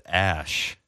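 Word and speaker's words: Æ: It's, I guess that's pronounced ash. Ash.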